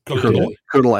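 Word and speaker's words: colonel: It came from the word, and Rook, you could probably handle this better than me, but coronel, I'm assuming, is how it's pronounced. And for some reason, the R stuck around in the pronunciation, even though there's no R in the spelling.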